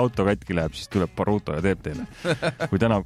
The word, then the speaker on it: Baruto: Baruto. Ehk siis, kuidas hääldati, Parto. – Parto või? – Jah.